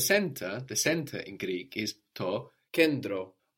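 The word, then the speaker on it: kendro (κέντρο): You can hear it like endi or like di. To kendro, to kedro. So this is spelled K-E-N, which looks like a V, no. T, it's spelled with the T, R, O. So N-T together inside of a word is going to give us a D or an N-D, not a T.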